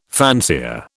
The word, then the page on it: fancier: British English